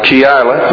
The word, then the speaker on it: Delilah: But Delilah, three syllables, the middle syllable having the accent on it.